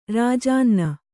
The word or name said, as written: ರಾಜಾನ್ನ